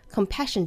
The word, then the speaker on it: compassion: People 依然是舌侧音 Compassion 这个词我们需要注意这个 S 的字母呢把它读成轻辅音的 SH Compassion. Compassion.